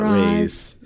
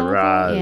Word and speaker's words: Ra's al Ghul: And Talia, of course, being a daughter of the supervisor, Ra's, or how do they pronounce it? It's not Ra's. – Ra's. – Yeah. There you go.